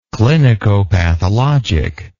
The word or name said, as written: clinicopathology